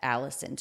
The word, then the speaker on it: allicin: But allicin, it's A-L-L-I-C-I-N. It's, I guess, pronounced allicin.